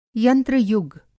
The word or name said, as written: यंत्र-युग